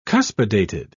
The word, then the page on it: cuspidated: British English